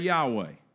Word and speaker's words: Yahweh: Now, we pronounce that in our English language, we pronounce that Yahweh.